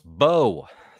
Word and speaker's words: Bew: Xavier says, Bew is a French name pronounced Beau.